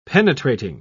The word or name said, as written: penetrating